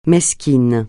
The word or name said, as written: mesquin